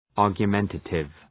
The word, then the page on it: argumentative: ,ɑ:rgjə’mentətıv